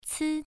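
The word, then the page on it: ci: tone 1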